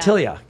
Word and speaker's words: Tillia: Did I say that correctly, Tillia? – Tillia. – All right, great. Tillia.